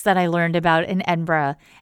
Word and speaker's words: Edinburgh: So if you ever get a chance to go, definitely go to Edinburgh, spelled Edinburgh, pronounced Edinburgh, like the thing half the population has to take off as soon as we get back home from work. And I'll put some pictures up on Twitter and on Instagram.